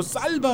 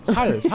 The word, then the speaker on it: 쌀: 경상도에 온 분들은 쌀 발음이 안 돼요. 살, 살 그래요.